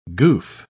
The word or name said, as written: goof